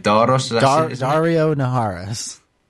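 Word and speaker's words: Daario Naharis: I can't pronounce his name. – Dario Naharis. – Dario Naharis, there we go.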